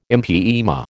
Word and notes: empyema: American English

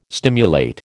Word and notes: stimulate: American English

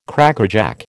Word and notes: Crackerjack: American English

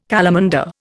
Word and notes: calamander: Indian

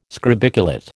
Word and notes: scrobiculate: American English

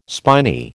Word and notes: spiny: American English

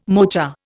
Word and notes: mocha: Indian

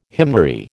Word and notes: hymnary: American English

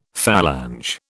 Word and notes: phalange: British English

